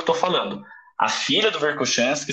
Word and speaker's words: Verkhoshansky: às vezes você vê eu falando Verkhoshansky, tá? que é a, é a pronúncia do nome em russo.